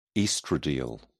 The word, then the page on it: oestradiol: British English